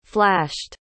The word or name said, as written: flashed